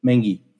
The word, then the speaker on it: Mengqi: Is it Mengqi Sun? Is that close? Mengi. – Mengi. Thank you.